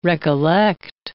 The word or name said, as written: recollect